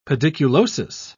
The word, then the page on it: pediculosis: British English